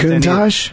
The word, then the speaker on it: Countach: How come I can't pronounce Countach?